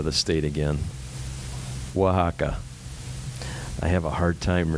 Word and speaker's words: Oaxaca: Oaxaca. – Okay. Got it. Yeah, Oaxaca.